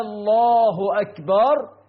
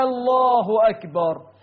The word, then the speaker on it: الله أكبر: منهم من يدخل همزة الاستفهام على لفظ الجلالة فيقول هكذا: الله أكبر.